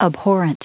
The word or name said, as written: abhorrent